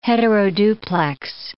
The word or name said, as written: heteroduplex